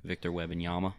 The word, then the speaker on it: Wembanyama: Wemba Dabumba. – Wambi Heba. – I don't know how to say his name. – Victor Wembanyama. – There you go.